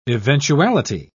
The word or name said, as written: eventuality